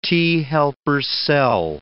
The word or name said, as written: T-helper cell